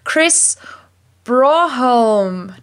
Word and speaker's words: Broholm: 12 of the Creative Language Learning Podcast, and that is Chris Broholm.